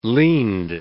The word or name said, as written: leaned